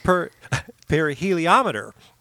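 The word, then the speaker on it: pyrheliometer: Perheliometer? – Very close. – The YR could be so many things. Perheliometer. – Almost. – All right, I give up. – It's perheliometer. – Oh, okay. – Like thermometer.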